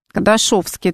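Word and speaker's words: Кадашёвский: Кадашовский, это через «ё» пишется раньше, по крайней мере, писался.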